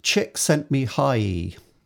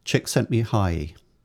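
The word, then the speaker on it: Csíkszentmihályi: It's pronounced Chick Sent Me Hi. Mihai, Chick Sent Me Hi.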